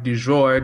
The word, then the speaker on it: du jour: How about we talk about the topic du jour? Du jour? I can't do it, man. – No, you said it right the first time, man.